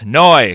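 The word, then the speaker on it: Neu!: I have to say it would be pronounced Neu because there's an exclamation point.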